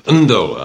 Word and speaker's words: Ndola: The return mailing address is Ndola Ndola, I believe it's pronounced, in Zambia, because apparently mail from Zaire goes best through Zambia nearby.